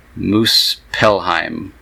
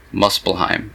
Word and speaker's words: Muspelheim: The answer is B, Muspelheim. Muspelheim. M-U-S-P-E-L-H-E-I-M.